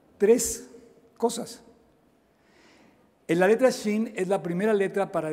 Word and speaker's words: Shin: Es la letra Shin, Shin, S-H-I-N. Eso no existe en nuestro alfabeto porque nosotros no pronunciamos la SH como griegos tampoco.